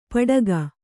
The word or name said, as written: ಪಡಗ